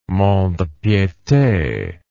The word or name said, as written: mont de piete